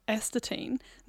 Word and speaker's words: astatine: We've just got this last story on a rare element, astatine.